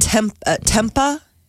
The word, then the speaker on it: tempeh: And I've never had tempa. I think it's how it's pronounced. T-E-M-P-E-H.